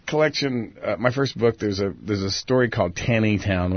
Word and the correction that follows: Tiny Town: which I actually mispronounced. The town in Maryland is actually pronounced Tiny Town, but I didn't know it at the time that I wrote it.